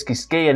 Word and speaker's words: Quisqueya: Quisqueya ni saben pronunciarlo, porque el Q-U-I no existe en inglés, así que lo escribí en inglés, como se dice Quisqueya en inglés, con K, Quisqueya Life, la vida en Quisqueya.